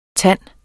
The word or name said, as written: TANDEN